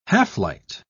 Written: half-light

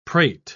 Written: prate

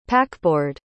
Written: packboard

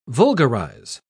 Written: vulgarize

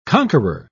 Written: conqueror

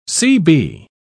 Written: cb

